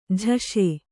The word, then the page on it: ಜೈಸು: jaisu